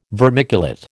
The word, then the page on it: vermiculate: American English